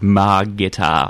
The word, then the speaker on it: Margitar: And it's actually spelt phonetically, so you have to pronounce it Margitar.